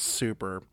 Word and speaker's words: SUP3R5: It's spelled like SUP3R5, but I think it's just called Super.